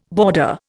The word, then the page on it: boarder: Indian